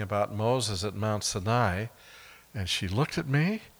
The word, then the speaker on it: Sinai: And that first time was at the foot of the mountain that is, yes, it's pronounced Sinai. I'm so glad to hear that. You know, English-speaking people make the two-syllable word three. They say Mount Sinai or something like that. But the A-I at the end of the Hebrew word is actually a diphthong, Sinai.